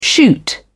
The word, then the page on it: shoot up: British English